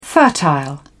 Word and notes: fertile: British English

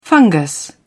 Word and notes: fungus: British English

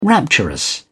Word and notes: rapturous: British English

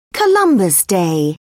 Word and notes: columbus day: British English

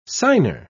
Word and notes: signer: British English

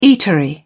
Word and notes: eatery: British English

